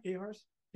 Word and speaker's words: AHARS: Now, all of you guys have said you're with AHARS, so the Advanced Helicopter Rescue School. Did I say that right? AHARS? Yeah.